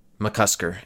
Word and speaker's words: McCusker: Is that how you say his name? – McCusker.